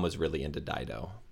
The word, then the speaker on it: Dido: I don't Dido. even know if that Dido. It's Dido. Yeah. It's Dido.